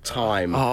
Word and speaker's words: thyme: he was saying it wrong, but he kept calling it thyme instead of time.